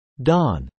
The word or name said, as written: dawn